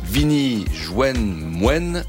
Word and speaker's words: Vini Jwen Mwen: Alors, faut le prononcer bien parce que c'est du créole. Vini Jwen Mwen, ça veut dire venez me rejoindre à la cantine et sur France Inter, évidemment.